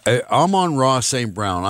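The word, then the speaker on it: Amon-Ra St. Brown: We're talking Lions and Bears and Vikings and the Amon St. Ra and Amon Ra St. Brown. I'm sorry, I got too many syllables there and I can't get them right.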